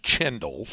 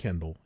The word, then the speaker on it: Kendall: Kendall spelled with a C-H, but it's not pronounced Chendall. – He pronounces it Kendall. And it's on multiple game broadcasts I found, it is pronounced Kendall.